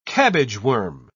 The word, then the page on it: cabbageworm: British English